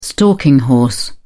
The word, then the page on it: stalking horse: British English